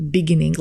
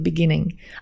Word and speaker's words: beginning: Že my sme zvyknutí hovoriť beginning, lebo v slovenčine máš prízvuk vždy na prvej slabike, ale v skutočnosti je to beginning.